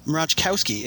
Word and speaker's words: Mroczkowski: Mroczkowski, if you absolutely have to.